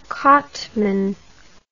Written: Cotman

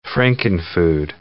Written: Frankenfood

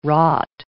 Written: wrought

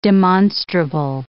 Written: demonstrable

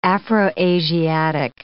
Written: Afro-Asiatic

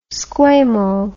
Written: squamo

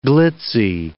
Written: glitzy

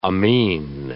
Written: amine

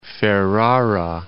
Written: Ferrara

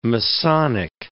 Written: Masonic

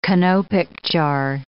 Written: canopic jar

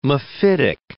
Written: mephitic

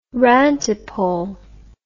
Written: rantipole

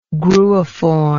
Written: gruiform

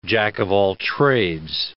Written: jack-of-all-trades

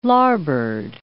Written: larboard

